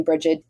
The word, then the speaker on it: Brigid: And you can find me on social media where I am at Being Brigid. My name is spelled Rigid with the B at the front of it.